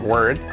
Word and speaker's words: word: Y enseguida nos vamos a la R bien marcada, word. Hace un efecto casi, no es como una E, pero casi, parece word, word, word, word, word, word, word. Y luego tenemos que hacer la D al final, word, word.